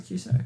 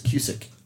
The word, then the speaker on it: Cusack: Who's John Cusack? – Cusack. Not Cus. You keep mispronouncing it. You say it's Cusick.